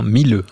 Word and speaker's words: Miele: Alors, on va le prononcer comme il faut, hein. Milleux, e, hein, maintenant. – Exactement.